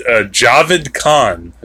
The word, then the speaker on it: Javed: About this guy named Javed Khan. I think I'm saying his name right. – Yes. – Yeah, I didn't say Jared, like in the paper in the movie.